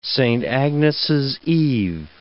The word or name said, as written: Saint Agnes' Eve